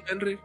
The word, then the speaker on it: Fenrir: Sí, Fenrir.